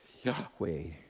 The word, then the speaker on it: Yahweh: What's beautiful about how we pronounce that now is you can almost breathe in on the first syllable and breathe out on the second. Yahweh.